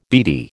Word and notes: beady: American English

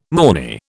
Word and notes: mornay: British English

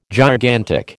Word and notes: gigantic: American English

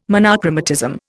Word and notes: monochromatism: Indian

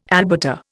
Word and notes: albata: Indian

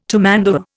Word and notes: tamandua: Indian

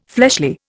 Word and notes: fleshly: Indian

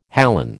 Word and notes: halon: American English

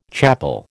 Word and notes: chappal: American English

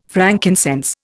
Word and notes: frankincense: Indian

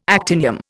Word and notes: actinium: Indian

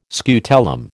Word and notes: scutellum: American English

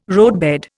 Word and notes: roadbed: Indian

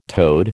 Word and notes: toad: American English

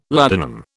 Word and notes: ladanum: British English

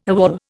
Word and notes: AWOL: Indian

